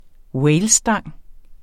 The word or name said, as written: walesstang